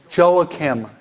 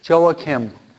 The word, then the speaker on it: Joachim: It's called Joachim. Say that. Okay. Joachim. Okay. And that's J-O-A-C-H-I-M. Spanish is called Joaquin, but in English we say Joachim, okay?